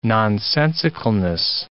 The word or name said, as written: nonsensicalness